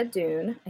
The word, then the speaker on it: Dunne: It's D-U-N-N-E. So I'm going to say Dunne. – I feel like it's either Dunne or Dunn. I've heard it pronounced both ways. I feel like Dunne is okay.